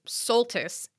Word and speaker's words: Soltis: And I should know this as someone whose maiden last name is Soltis, which was constantly mispronounced as Solstice.